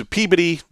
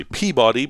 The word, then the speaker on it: Peabody: Every morning, he'd come over at 10, and we'd walk down through Salem into Peabody, which most people would say Peabody, but the correct pronunciation is Peabody, to a place called Metro Bowl, which was right there in Peabody Square.